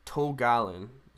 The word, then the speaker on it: Tol Galen: And they go and live in, Tol Galen. Is that how you say it? – Yeah, yeah.